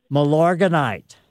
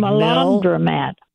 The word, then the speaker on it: Milorganite: Milorganite. So it's like Milandromat.